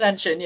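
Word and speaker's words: Senyshyn: How about the third guy there, Zachary Senshin? Shenshine? How do you pronounce it? Senshin? – Senshin, yeah.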